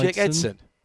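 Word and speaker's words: Eidson: Jacob Idesen. – Jake Edson. – Edson? – Jake Edson. Yes.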